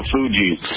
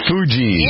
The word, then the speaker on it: Fugees: Make sure you pronounce it correctly, please. – The Fugees. – Fugees, yeah. Which is short for refugee.